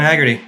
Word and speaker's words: Heagerty: yeah yeah michael john haggerty that's where it's at it's in the it's in the title See down below.